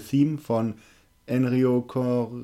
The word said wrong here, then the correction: Ennio Morricone: Ennio Morricone.